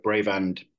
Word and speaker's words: Bravand: We get Bravand and Bravand, but Bravand is the name and there's a story behind that.